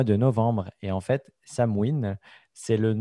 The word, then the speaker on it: Samhain: Alors, ça se prononce Samhain. Ça s'écrit Samhain, effectivement, Jean-François, mais ça se prononce Samhain. C'est du gaélique, l'écossais ancestral. Et effectivement, c'est il y a 2500 ans. En irlandais, on va dire Samhain.